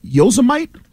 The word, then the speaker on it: Yosemite: Looks like Yosemite.